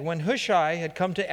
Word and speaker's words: Hushai: Hushai's deceptive advice. Some people pronounce it Hushai, others Hushi. I'm going to go, or Hushi. I'm going to pronounce it Hushai, okay? There's about three or four different pronunciations.